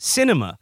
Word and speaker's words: Sinema: It says here, the Arizona senator's name is pronounced Sinema,